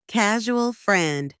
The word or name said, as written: casual friend